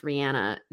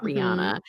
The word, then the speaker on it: Rihanna: like I, I really love Rihanna, and her name is pronounced Rihanna, not Rihanna.